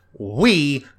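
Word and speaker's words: oui: It's pronounced we, but it's spelled oi, and it's like, no, that is a crime.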